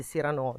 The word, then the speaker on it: Cyrano: Quindi che farebbe Cyrano, anzi Cyrano, diciamolo bene, che farebbe Cyrano, giusto? O va pronunciato... – In realtà dipende dal personaggio che lo pronuncia, perché... – Ah, spoiler. – C'è uno che lo pronuncia in un modo, l'altro che lo pronuncia in un altro. Sarebbe Cyrano di Bergerac, però... – Scelgo di pronunciarlo alla francese, come sarebbe giusto.